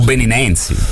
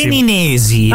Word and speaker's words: beninesi: Beninosi? – Benini. – Benini, ma non benissimo. – Beninesi. – Ah, beninesi. – L'hai detto bene alla prima, vedi? – Beninesi.